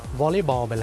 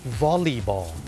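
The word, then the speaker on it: volleyball: วอลเลย์บอลไปแล้วเนี่ยคนไทยเนี่ยเมื่อพูดถึงกีฬาวอลเลย์บอลเนี่ยออกเสียงว่าวอลเลย์ถูกไหมครับวอลเลย์บอลแต่จริงๆแล้วนะครับเจ้าของภาษาเนี่ยไม่ได้ออกเสียงว่าวอลเลย์บอลนะครับเขาออกเสียงว่าวอลลีบอลวอลลีบอลตัว LEY เป็นออกเสียงลี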